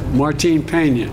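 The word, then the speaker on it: Martín Peña: help me with the pronunciation. – Martin Peña, that entire ecosystem.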